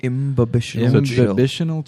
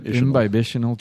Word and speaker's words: imbibitional: Imbibitional chill. – Yep. – So it's imbibe and then... – Yep, imbibitional chill, yep.